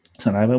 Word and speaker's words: Sonoma: Yeah, Sonoma.